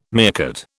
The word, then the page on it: meerkat: British English